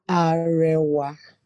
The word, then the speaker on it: Arewa: Yeah, that's because you're pronouncing it as Arewa. It's actually Arewa.